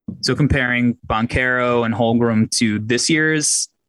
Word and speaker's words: Banchero: I'm sure if I'm pronouncing his name right. – Banchero. – Okay. – Yep.